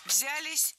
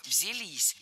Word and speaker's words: взялись: Взялись неправильно. Здесь нужно ударять последний слог. Взялись. И скажу по секрету, глаголы с частью «с» очень часто притягивают к себе ударение.